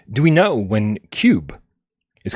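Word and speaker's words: Cyube: It's spelled weird, too. It's like C-Y-U-B. – It's like Cube.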